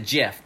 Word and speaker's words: GIF: But the guy that started it pronounces it Jif, but that's already a peanut butter. – Well, and he says it's Jif like the peanut butter, and you go, then spell it J-I-F, you idiot.